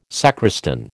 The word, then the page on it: sacristan: American English